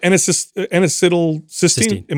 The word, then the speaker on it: N-acetylcysteine: You brought up N-acetylcysteine.